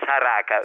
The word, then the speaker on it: saraca: Alla saraga, ha detto? – Sì, la saraca.